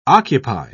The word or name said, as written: occupy